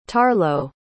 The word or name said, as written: tarlo